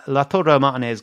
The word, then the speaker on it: Lautaro Martinez: We're buying Lautaro Martinez goals minutes, sorry, player goal minutes at 34.